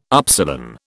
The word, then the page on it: upsilon: British English